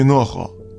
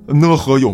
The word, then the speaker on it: 讷河: 当年呢，有这么一句话啊，叫不想活，去讷河。这讷河呢是这个东北的这么一个地名儿，这个字儿我平时一直读成讷，啊，但是说这个有东北的朋友指指正我啊，说你必须得读讷。